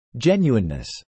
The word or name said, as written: genuineness